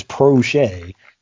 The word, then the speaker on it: Proche: James Prochet. And I had to look that up to make sure I got the pronunciation right, because I think I was calling him Proche, but it's Prochet.